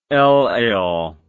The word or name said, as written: elayl